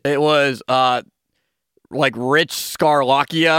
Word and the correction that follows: Bisaccia: How do you pronounce his name? – Rich Bisaccia. – Yeah, I was very close. – Or Bisaccia.